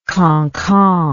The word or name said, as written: kankan